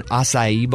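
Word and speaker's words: açaí: I'd like one of those acai bowls, please. – Mm-hmm. – And they said, excuse me? And I was like, an acai bowl? They were like, you mean an acai bowl? And I was like, okay, fancy schmancy. So I only know that A-C-A-I is acai because I was shamed.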